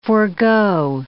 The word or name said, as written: forego